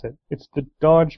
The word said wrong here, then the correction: Doge: meme. Anyone? No, dog meme. – Oh yeah, Doge. – Doge. There you go, Doge.